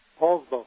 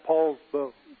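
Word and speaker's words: Poulsbo: Poolsbo. – Poolsbo. Okay, gotcha. – Like the Apostle Paul. Poolsbo. – Pronounced like Paul. Okay, it's spelled differently, but sounds like it.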